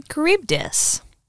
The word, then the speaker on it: Charybdis: Charybdis. – I'll probably never say it again. – Like, like Karen, right? It's spelled with the C-H, that Greek C-H that always gets us.